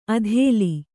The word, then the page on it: ಅಧೇಲಿ: adhēli